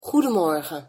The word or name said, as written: goedemorgen